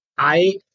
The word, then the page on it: ai: Sanskrit